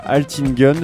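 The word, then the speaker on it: Altın Gün: pour euh, inaugurer ce tour du monde des musiques euh, qui redonnent foi en l'humanité, tu voulais commencer par euh, Altingen, je sais pas si je le prononce bien. – C'est parfait.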